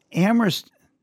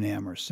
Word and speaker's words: Amherst: Amherst, people like to joke, is the place where only the H is silent.